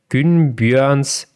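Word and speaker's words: Gunnbjørn: Meter hoch ist der Günbjörns Fjeld. Also ich hoffe, ich habe das jetzt richtig ausgesprochen. – Ich, glaube, man sagt Günbjörn.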